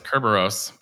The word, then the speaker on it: Kerberos: It's a lot easier to say than Kerberos. – Is that how you pronounce that? – Yeah, that was Kerberos.